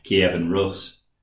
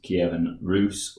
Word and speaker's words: Kievan Rus: No, yeah, Ben very kindly messaged us from Middle Earth and told me that it's not Kievan Rus, it's Kievan Rus, like goose or juice.